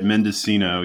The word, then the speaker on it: Mendocino: Is it Mendocino? Am I pronouncing that right? – No, you got it. Mendocino.